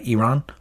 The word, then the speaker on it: Iran: Beat Iran or Iran. Iran, a guy got yelled at for saying it wrong. I'll say it right. Iran.